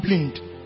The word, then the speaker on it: Blind: There's a footballer called Blind. It's actually pronounced Blind. It's a Dutch footballer, Danny Blind.